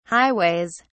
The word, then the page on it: highways: British English